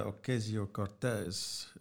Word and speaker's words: Ocasio-Cortez: Yeah, and the other big thing, just sticking with the American theme at the moment, is Alexandria Ocasio-Cortez, the sort of East New York.